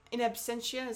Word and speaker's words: in absentia: Next up, we have our in absence. Was it in absentia? Is that how you say it? That's the phrase? – Something like that, yeah.